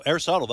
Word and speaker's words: Aristotle: Aristotle. – No, Aristotle, that's right.